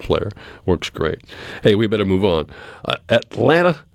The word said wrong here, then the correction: Alanis: Morissette. – Alanis. – I knew I was going to say that wrong. Alanis Morissette.